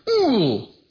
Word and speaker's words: ool: And the very interesting about this verb is called or pronounced as ool, ool. Okay? It's O-O-L if you want to put it that way.